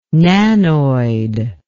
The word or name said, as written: nanoid